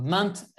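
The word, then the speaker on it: Pierra Menta: Ah et puis pour ceux qui ne connaissent pas la, la, Pyramenta ou Pyramente, euh, allez voir sur euh, YouTube des, vidéos. Donc, vous tapez Piera, P-I-E-2-R-A, et euh, Mint, M-E-N-T-A, parce qu'on ne prononce pas le A.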